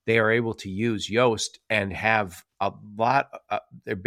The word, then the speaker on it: Yoast: My people use Yoast, which is like Toast, but it's spelled with a Y instead of a T at the beginning.